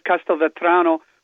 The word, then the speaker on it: Castelvetrano: You did it great. I'll go with you on what the pronunciation for escabeche. I've always called escabeche, but we'll go with whatever it is. And then it's Castelvetrano. But I got to tell you,